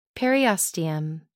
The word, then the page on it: periosteum: American English